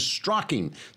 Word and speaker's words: Strzok: The process of redrafting FBI documents has now become known as Strzoking. That's spelled S-T-R-Z-O-K. The Z is silent, and so is the FBI.